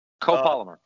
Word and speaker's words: copolymer: I fished it a lot last year, but the copy polymer, am I saying it right? – Copolymer. – Copolymer line.